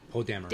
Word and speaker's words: Poe Dameron: The Poe Dameron, is that how you say his name? – Poe Dameron.